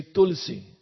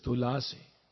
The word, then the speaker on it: Tulasi: For example, in Hindi, you say Tulsi. But in Sanskrit, it's Tulasi.